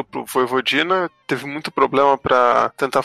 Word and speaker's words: Vojvodina: É, é, é assim, assim é, se escreve Vojvodina, né? Mas no idioma Exato. eslavo o J tem som de I, então fica Voivodina